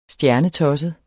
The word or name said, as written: stjernetosset